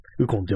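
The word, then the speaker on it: 鬱金: ウコン漢字で書くと、あの、うつ、憂鬱のうつに、金って書いてうつ、ウコンって読むらしいですね。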